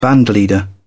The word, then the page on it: bandleader: British English